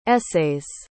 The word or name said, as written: essays